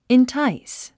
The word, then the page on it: entice: intáis; インタァイス